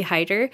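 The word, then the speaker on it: Haider: It's not Hader. It's Hider.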